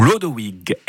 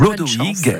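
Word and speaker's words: Chlodowig: Ben oui, dont mon fils vient d'un drôle de mot germanique, très ancien, je vais essayer de vous le prononcer à la germanique, ou Lodowig. Et ou Lodowig, eh oui, qui a été transformé en Clodovec, étonnamment, en Clovis, en Lovis, puis en Louis.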